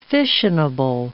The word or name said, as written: fissionable